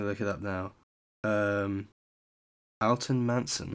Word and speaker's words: Alton: I'm gonna pronounce it Alton, I think, because it's like Alton Towers. It's spelled the same.